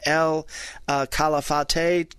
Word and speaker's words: El Calafate: And we're going to go for another 3 hours to get between El Calafate and El Chalten. – Very nicely pronounced.